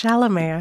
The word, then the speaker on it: Chalamet: Well, it's Chalamet.